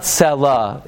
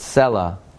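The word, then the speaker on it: Selah (סלה): It's not Selah, it's Selah.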